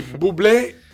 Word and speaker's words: Bublé: Allora, sono tutti con l'accento sulla E. Sì. Quindi Bublé il papà e il nonno paterno si chiamano Bublé.